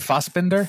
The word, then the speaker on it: Fassbender: Fassbender.